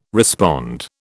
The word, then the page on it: respond: British English